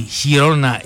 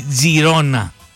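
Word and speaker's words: Girona: Η Χιρόνα ή Τζιρόνα, μπορείτε να την προφέρετε όπως θέλετε.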